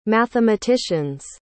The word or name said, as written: mathematician's